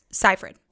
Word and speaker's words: Seyfried: How do you pronounce it? – Seyfried.